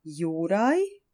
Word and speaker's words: Juraj: It's J-U-R-A-J, Juraj, which is kind of cool because it almost rhymes with his friend, the dragon, in Croatian, Zmaj.